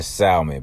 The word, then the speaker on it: salmon: And let's all come together and let's all pronounce the L in Salmon, because it's ridiculous.